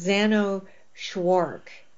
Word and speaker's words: Jeannot Szwarc: So let's veer on to, so we're covering two ABC Movie of the Weeks directed by, now I have been pronouncing this man's name, Jeannot Szwarc, and I was pretty sure that I had the first name right and the last name maybe right, but it does, turns out I don't have either one right. Apparently it's Jeannot Szwarc.